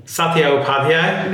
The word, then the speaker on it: Satya Upadhyaya: Satya Upadhyaya.